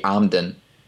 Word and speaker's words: Emden: The E pronounced as an A as an and, so that would be Amden.